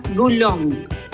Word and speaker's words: Boulogne: Claro, que yo, como es palabra en francés, la pronunciaría Boulogne. – Claro. – ¿O no? – Sí, puede ser. También. – Pero acá le pregunté, digo, ¿cómo se pronuncia? Me contesta. A ver. Un segundo, que le pongo play acá. – ¿Qué haces, amigo? Hola. – A ver. – Al ser francesa se pronuncia Boulogne.